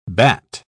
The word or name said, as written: batt